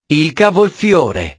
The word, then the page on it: il cavolfiore: italiano